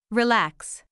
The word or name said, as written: relax